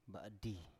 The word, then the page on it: ba7di: Biak